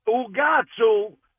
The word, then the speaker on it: ucazzu: Ugatsu. It's spelled U-C-A-Z-Z-U. Ugatsu. And Sicilian, they pronounce the C as a G. So that's where you get the Ugas from.